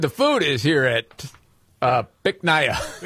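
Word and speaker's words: Bich Nga: Which he was going to have a little fun with, because the name of the restaurant, you pronounce it Bic Naya. – Bic Naya? – Yes, but it's spelled B-I-C-H, one word. Yeah. Second word, N-G-A.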